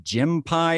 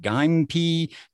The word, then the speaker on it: Gympie: And I hate to say, I even looked up, it's G-Y-M-P-I-E. And I even looked up thinking, is it Jim Pie? Is it Gympie? But no, it's pronounced- It's like pygmy spelled. – But it's pronounced Gympie. Oh.